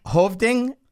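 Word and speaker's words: Hövding: It's called, and I'm going to try to pronounce it, it's a Swedish. – Okay. – Hovding, H-O-V-D-I-N-G.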